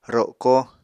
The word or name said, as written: roko